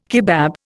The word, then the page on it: kebab: Indian